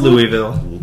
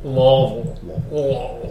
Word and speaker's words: Louisville: And they, it's pronounced Louisville. – Louisville.